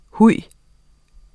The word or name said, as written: huj